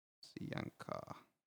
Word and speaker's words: Sianca: But the song that I kind of always go back to is Sianca. Sorry, it's just loading. I'm gonna edit this part out. Oh, New Phone, Who Dis? So that's what it's called, and it's spelled exactly how I pronounced it.